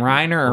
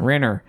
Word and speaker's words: Renner: How do you say his name? Reiner or Renner? – Renner.